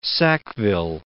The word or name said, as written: Sackville